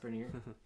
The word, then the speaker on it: Brenner: So it's written as Brenner. – Right. – To be pronounced Brenier.